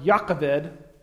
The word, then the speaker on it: Jochebed: There's not really a hard J in Hebrew, but I'm probably going to refer to her as Jochebed the rest of the day, because that's how we spell it phonetically.